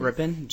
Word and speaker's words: Grippen: Hi, my name is Jacob Grippen, and I came up today from Houston County. – Jacob, can you say your last name again, please? – Grippen, G-R-I-P-P-E-N.